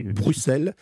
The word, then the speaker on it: Bruxelles: Ce sont des S. Hein, donc il faut dire Bruxelles.